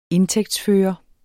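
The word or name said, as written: INDTÆGTSFØRE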